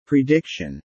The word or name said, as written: prediction